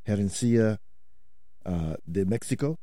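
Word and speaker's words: Herencia de México: Some new music from Mariachi Herencia de Mexico. Did I come close to that? – Yeah, pretty close.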